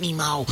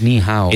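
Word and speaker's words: Ni Hao (你好): It's no, it's not. It's not Ni Mao. – Yeah. – How do I say it? – It's Ni Hao.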